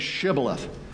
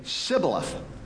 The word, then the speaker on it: Shibboleth: And when he said, "No," they said to him, "Then say Shibboleth." And he would say, "Sibboleth." for he could not pronounce it right.